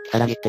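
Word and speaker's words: きさらぎ駅: とりあえず駅を出てみたら、今ちょっと調べたら、鬼って書いて、キサラギって読めるのね。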